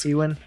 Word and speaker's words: Ewan: I never can say his name right, because it's Ewan. – Spelled it looks like E-W-A-N.